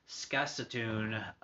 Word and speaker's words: Saskatoon: Chip from Ric Flair and Skax, trying to pronounce this correctly here, Saskatoon, yep, I pronounced it right, Saskatoon,